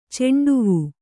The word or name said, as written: ಚೆಂಡುವೂ